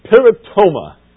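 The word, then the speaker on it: Paratoma (παράπτωμα): Paratoma is the word. Paratoma. You're writing it. P-A-R-A-T is the word. You've got to have a T in there. P-A-R-A-P-T, sorry. P-A-R-A-P-T-O-M-A. That second P is silent.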